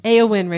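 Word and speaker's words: Éowyn: Eowyn, Richie.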